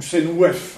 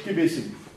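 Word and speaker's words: Qebehsenuef: Donc ça c'est Kébé-Sénouf. Kébé-Sénouf. Ou Sénouef, hein, que on trouve le fois par. Enfin, ça se prononce plutôt Kébé-Sénouf.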